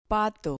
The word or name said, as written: pato